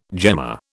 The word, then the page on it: gemma: British English